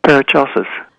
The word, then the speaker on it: Paracelsus: One of them was, I'm not sure how to pronounce his name, Paracelsus? – Paracelsus.